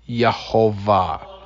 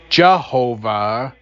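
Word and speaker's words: Yehovah (יהוה): it shows that his name is pronounced Yehovah. We use Jehovah as an English transliteration of the Hebrew word Yehovah.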